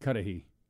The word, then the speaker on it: Cudahy: Wishy is playing in, how do you pronounce that? – Cudahy.